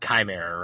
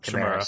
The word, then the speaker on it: Chimera: It's Chimera, right? Do I say it right? I say Chimera, yeah. – Chimera.